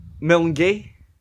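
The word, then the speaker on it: Milngavie: Okay. It's Milngay. – Mulgay. – Which is Mulngavy.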